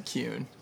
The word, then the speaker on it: Kuehn: Kuehn.